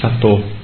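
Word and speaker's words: Sattva: Thì Tây Tạng nó đọc chữ Satwa không được, nó đọc là Sato.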